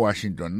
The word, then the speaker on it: Washington: Si n'a pas mettez accent sur accent encore, ou après que mot Washington, non, accent tombé sur premier syllabe là.